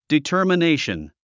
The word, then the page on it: determination: ditə̀ː r mənéiʃən; ディターマネイション